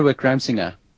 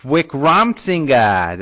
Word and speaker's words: Wickramasinghe: You could probably say it better. You say his name often. Wickramasinghe? – Chandra Wickramasinghe. – Wickramasinghe. There you go. See, you get it right.